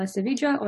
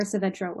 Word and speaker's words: Savedra: Isabella Savedra or Savedra works.